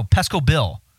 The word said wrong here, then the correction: Pecos Bill: Why did I call him Pesco Pete? Pecos. Oh, no. It's Pecos Bill. I'm mispronouncing it.